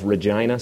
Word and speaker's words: Regina: Regina, Saskatchewan. And yes, that is the correct pronunciation.